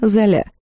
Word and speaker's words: Золя: «Это мои подопечные, граф Карл Вест, госпожа Кира Золя». – Я округлила глаза, когда моя фамилия вдруг тоже потеряла половину букв и приобрела ударение на последний слог.